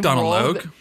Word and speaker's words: Donald Logue: There's never been a more Donald Long, or like however you say his name. – Donald Logue? – Yeah, that guy.